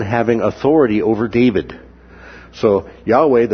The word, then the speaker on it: Yahweh: Yahweh is the personal name of God. Sometimes that's pronounced Jehovah, although that's not the most correct way to pronounce it.